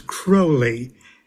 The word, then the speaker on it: Crowley: Well, the way Aleister Crowley pronounced it was Crowley, and he said it rhymes with holy.